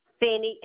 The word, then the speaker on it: Finney: Finney, F-I-N-N-E-Y.